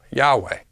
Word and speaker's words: Yahweh: I-A-U-A, iota, alpha, upsilon, and alpha, pronounced as Yahweh.